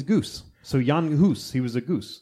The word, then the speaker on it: Jan Hus: Okay, now in the Czechoslovakian, he's Czech. – Right. – And you would pronounce his name Jan Hus. – Jan Hus. – Jan Hus. But I'm going to say John Hus because I'm English, because I'm American.